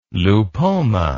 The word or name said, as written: lupoma